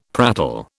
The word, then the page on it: prattle: British English